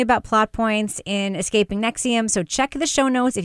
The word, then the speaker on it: NXIVM: Nexium, that is spelled, by the way, N-X-I-V-M, all capital letters.